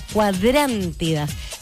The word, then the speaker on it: cuadrántidas: Y después vamos a tener una lluvia de cuadrántidas. Ahí está, es que tiene acento en la cuadrántidas.